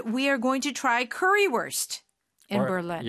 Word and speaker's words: Currywurst: And for those of you who may not know German, but the W there is pronounced as a V. – Yeah. – So it's spelled worst, but it's wurst.